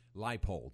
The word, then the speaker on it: Leipold: Is it Lance Leopold? No, Leipold. – Lance yeah. Leipold.